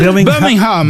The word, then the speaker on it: Birmingham: On ne doit pas prononcer le G, hein, il paraît. – Birmingham.